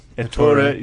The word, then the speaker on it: Ettore: Founder Ettore Bugatti. Am I saying that right? – No, you're not. – Ettore, you see.